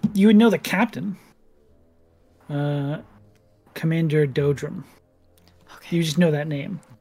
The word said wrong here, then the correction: Dobrum: Oh, D-O-B-R-U-M. – Dobrum. – Oh, yeah, Dobrum. I said it wrong.